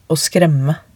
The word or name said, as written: å skremme